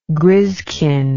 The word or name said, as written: griskin